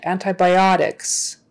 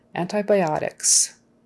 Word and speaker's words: antibiotics: The stress is actually near the end. Antibiotics, otics, the second to the last, antibiotics. Because it has so many syllables. So, antibiotics.